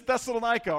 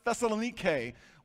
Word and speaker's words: Thessalonica: The city is Thessalonica, or you can also pronounce it Thessalonike. We will use Thessalonica. It's a little easier for us to remember.